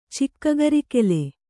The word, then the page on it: ಚಿಕ್ಕಗರಿಕೆಲೆ: kannada